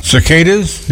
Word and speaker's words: cicada: Okay, cicadas? – Yes. – Okay. – Those things are creepy, too. – I said it right? – Yeah, cicada, that's right.